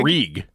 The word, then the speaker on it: Greig: Ridley Greig? – Greig. – Ridley Greig. – Ridley Greig.